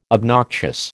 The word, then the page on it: obnoxious: American English